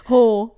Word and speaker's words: Hoare: Whore. H-O-A-R-E, pronounced whore.